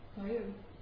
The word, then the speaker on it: Bayern: No, I isn't the German word B-A-Y-E-R. Bavaria is the English translation. – Okay. – Bayern. Bayern. – How do you say Bavaria in German? – Bayern.